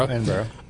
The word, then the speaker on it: Edinburgh: Edinburgh. I'm not sure how to pronounce it. – Edinburgh. – Edinburgh.